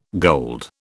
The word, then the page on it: gold: British English